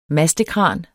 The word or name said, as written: mastekran